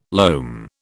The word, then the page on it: loam: British English